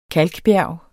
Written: kalkbjerg

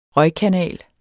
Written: røgkanal

